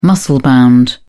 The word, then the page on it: muscle-bound: British English